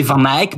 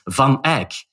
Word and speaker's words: Van Eyck: But you criticized, there was a hint there that you don't pronounce Van Eyck like we do. – Yes. You say Van Eyck, but he's Van Eyck.